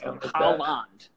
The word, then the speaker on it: Haaland: It's Haaland. Haaland. I need to pronounce the D at the end.